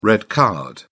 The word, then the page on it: red card: British English